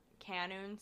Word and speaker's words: Kenoun: And also follow me on Instagram at Sabrina Canoon. So my name and my last name is spelled K-E-N-O-U-N, no underscores, no periods or anything.